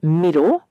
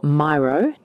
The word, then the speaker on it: Miro: If we are doing our quarterly planning virtually, I use a tool called Miro, or Miro, depending on how you like to pronounce it. It is M-I-R-O, and it is an electronic whiteboard where you can use stickies and all kinds of very cool tools.